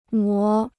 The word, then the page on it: 磨: mó